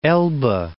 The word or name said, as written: Elbe